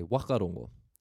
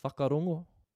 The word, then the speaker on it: whakarongo: They'll say wakarongo. So while the people up north drop the W and keep the H, the people on the west coast of the North Island drop the H and keep the W. If we go to the Bay of Plenty area where the Tūhoe tribe are from, they don't pronounce the digraph NG, ng. They drop the G and just use N. So we have, instead of whakarongo, whakarono.